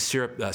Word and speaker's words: SYRUPP: Syrup that's spelled SYRUPP, and that's based off the acronym SRPP, which, bear with me, stands for series regulated push pull.